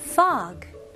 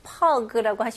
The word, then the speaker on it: fog: 이거는 발음을 F 발음으로 하셔야죠. 그래서 pog라고 하시면 안 되고 입술이 붙으면 안 돼요. fog 그리고 G 발음을 명확히 잘 해주셔야 되고요. 여기서는 이 O 발음, 이거는 입술이 동그래지면 안 되고요. 이게 턱이 내려가는 느낌으로 fog. fog 이렇게 나와야 됩니다. 그리고 모음이 살짝 길면 더 좋고요.